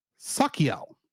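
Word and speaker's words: Sachiel: is Sakiel, mm-hmm. – though it's spelled as C H I E L.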